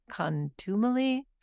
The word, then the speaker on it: contumely: but I think it's pronounced something like contumely, contumely.